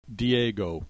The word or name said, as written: Diego